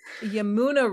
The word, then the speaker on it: Yamuna: Um, of the Yamaka, or no, I'm saying that wrong. Yamunka River. Yamun. – You've Wait got this. It. Loading so hard. – Um, Yamuna River.